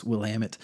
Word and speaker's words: Willamette: So it is not Willamette, it's Willamette. Um, but anyhow, so the same DA that we talked about when I mispronounced Willamette.